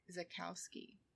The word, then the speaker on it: Czajkowski: So, as far as our bad nurse story for this week, this is the story of Dennis Zakowski. I guess that's how you say that. Does that sound right? – Yeah. – Okay. Dennis Zakowski, and it's spelled C-Z-A-J-K-O-W-S-K-I.